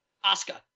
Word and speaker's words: Asuka: Do I say her name right still, or do I still? – No, you always say it brutal, Asuka. But we don't like to correct you because we love that you constantly, you know. – Not only did he not win it, I felt that he lost it. – We love when you butcher that. – It's Asuka.